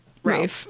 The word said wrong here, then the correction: Ralph: Rafe. Gosh. Guys, it's spelled Ralph.